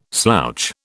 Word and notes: slouch: British English

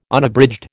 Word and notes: Unabridged: American English

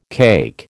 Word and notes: cake: American English

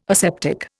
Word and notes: aseptic: Indian

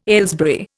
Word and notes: Aylesbury: Indian

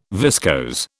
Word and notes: viscose: British English

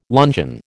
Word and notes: luncheon: American English

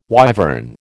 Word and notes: wyvern: American English

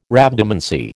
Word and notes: Rhabdomancy: American English

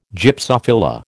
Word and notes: Gypsophila: American English

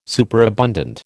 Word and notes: superabundant: American English

